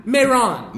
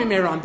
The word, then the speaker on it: Mehran: Mehran. – Mehran. – You can call me Mehran.